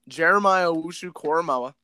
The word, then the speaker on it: Jeremiah Owusu-Koramoah: Jeremiah Owusu-Koramoah. I'm going to continue to flex the fact that I know how to pronounce it. – Oh, boy. That's why I go with the solid J-O-K.